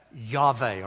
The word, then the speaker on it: Yahweh: So if you looked at YHWH in a Hebrew manuscript and you knew the language, you knew to pronounce that Yahweh or Yahweh. The vowels you just understood. In English, we need the vowels.